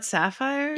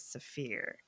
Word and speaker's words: Saphir: Did you say Saphir? It's not sapphire? – Yeah. – Stop. – Saphir. I don't know. It's Saphir. It's a German hop variety. I've always heard it pronounced as Saphir.